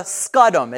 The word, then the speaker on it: scutum: It's actually called the scutum. It's spelled S-C-U-T-U-M.